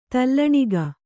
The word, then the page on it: ತೌಲನಿಕ: Kannada